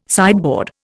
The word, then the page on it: sideboard: Indian